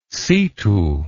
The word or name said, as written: situ